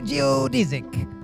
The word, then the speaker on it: geodesic: In fact, both pronunciations are acceptable. You say geodesic, I say geodesic.